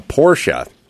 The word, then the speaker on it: Porsche: I never have driven a Porsche.